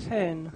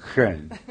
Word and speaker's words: Hohn: Sí, H-O-H-N. – H-O-H-N. ¿Y cómo se dice? – Hen. – Hen. Es alemán.